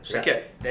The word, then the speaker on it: Shaked: Did I say your name right? – Shaked.